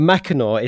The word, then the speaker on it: Mackinac: But Mackinac is not spelled as it sounds. It is M-A-C-K, and then it's I-N-A-C. So that's how you spell Mackinac.